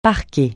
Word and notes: parquer: francais

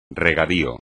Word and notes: regadío: espagnol

